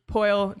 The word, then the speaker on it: Poile: David Poile, that's how you say his name, right? – Yep.